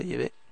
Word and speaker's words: Yahvé: No Jehová, porque Jehová es un nombre falso, por eso es que yo lo llamo Yahvé. Parece ser que es la pronunciación correcta, Yahvé.